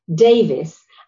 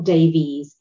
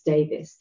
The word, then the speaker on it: Davis: So it's Nicola, can't think of anything that rhymes with that, Davis, and in spite of the fact that it's spelled with an E, it's not Davies, it's Davis.